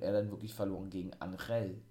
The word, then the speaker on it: Angel: Sie heißen ja nur noch Umberto und Angel oder Angel, ne, so wurde der eigentlich ausgesprochen,